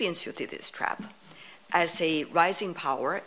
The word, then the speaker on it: Thucydides: The Thucydides trap. I hope I pronounced it well. – You pronounced it exactly right.